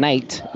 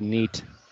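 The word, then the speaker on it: Neyt: Hayden Knight is how it's pronounced. It's N-E-Y-T. I was going with Neat. It's Neat that he pronounces it Knight.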